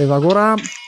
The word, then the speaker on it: Evagora: Evan eh, Evagora, Evagora, scusate, io ce lo metto sempre l'accento a sto qua, non so perché.